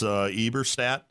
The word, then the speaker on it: Eberstadt: and I'm going to mispronounce his name, Nicholas Eberstadt. Is that how you pronounce it? – I think so.